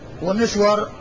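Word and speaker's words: مِسْوَر: مسور ومسور، مسور بضمن الميم وفتح السين والواو المشددة، ومسور بالميم المكسورة والسين الساكنة والواو المفتوحة. ففيه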